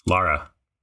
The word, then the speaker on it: Lara: Pronounce this word for me. I'm going to spell a word for you, and I was hoping you could pronounce it for me. Lara. – Lara. – You see, you were almost there that time. You were almost there. – He said Laura before. I heard it.